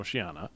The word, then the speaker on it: Oceania: O-C-E-A-N-I-A. – Okay. – Yep, but it's pronounced Oceana.